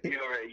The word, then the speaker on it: Fiore: Yeah, Fiore.